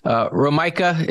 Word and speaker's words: Romeike: How do you say that name again? – That's a good question. Romaika is how I've heard it pronounced most.